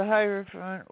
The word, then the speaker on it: Hierophant: Is it the Hierophant card? The Hierophant? Am I saying it right? – Yeah, the Hierophant.